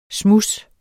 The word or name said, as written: smuds